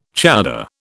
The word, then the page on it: chowder: British English